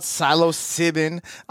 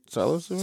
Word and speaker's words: psilocybin: And I'm not talking about psilocybin. I'm talking about the shroom supplementation. – Who's Regis Philbin? – What? – What'd you just say? – Silo, is it? Did I say it right? Psilocybin? – Sounded like you said Regis Philbin.